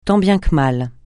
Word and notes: tant bien que mal: francais

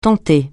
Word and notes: tenter: francais

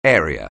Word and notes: area: anglais

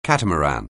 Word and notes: catamaran: anglais

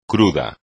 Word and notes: crudo: espagnol

